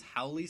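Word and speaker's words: haole: But Ashla's like, actually, if you read that, they would pronounce Howley City,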